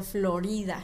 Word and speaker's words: Florida: En español se pronuncia Florida.